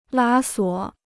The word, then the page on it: 拉锁: lā suǒ